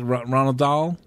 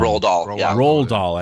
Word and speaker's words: Roald Dahl: Is it Ronald Dahl? I always say his name wrong. – Roald Dahl. – I always say Ronald.